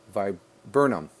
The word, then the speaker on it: viburnum: And now getting to the cover art, that's a picture I took just after the walk here of my Korean spice Verdum. I can't pronounce that last one. Viburnum.